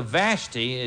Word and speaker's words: Vashti: Vashti, actually, today we would spell it V-A-S-H-T-I. So Vashti, it's actually Vashti, for pride was set aside. What was that about? You know anything about Vashti? V-A-S, take the T and the H and switch them.